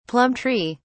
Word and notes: plum tree: British English

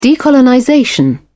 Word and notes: decolonization: British English

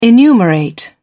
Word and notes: enumerate: British English

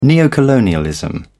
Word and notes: neocolonialism: British English

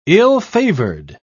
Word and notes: ill-favoured: British English

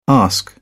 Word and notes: ask: British English

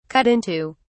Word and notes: cut into: British English

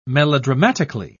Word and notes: melodramatically: British English

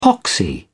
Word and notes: poxy: British English

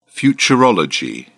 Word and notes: futurology: British English

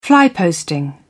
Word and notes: fly posting: British English

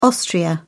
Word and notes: austria: British English

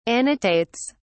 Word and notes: annotates: British English

